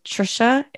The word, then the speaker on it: Tricia: On social media, on Instagram, it is Trisha, and it's a 1970s name. No one uses it anymore. So it's spelled T-R-I-C-I-A.